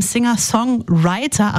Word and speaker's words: Singer-Songwriter: Jetzt die beste aktuelle Musik mit einem Singer-Songwriter. Achtung, Betonung auf Writer.